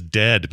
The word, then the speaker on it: Dehd: His name is Dead, but it's spelled D-E-H-D.